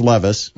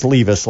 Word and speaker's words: Levis: If Levis, well, I believe it's pronounced Levis. – Yes. – I mispronounced it as Levis last week, and so I'll apologize.